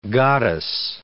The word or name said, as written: goddess